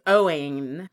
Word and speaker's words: Owain: O-W-A-I-N. It's a different spelling of Owen. – Yeah. – You can still pronounce it Owen, or you can go Owen, which everyone's going to do anyway. And then for Ava, I suggest Aviella. A-V-I-E-L-L-A.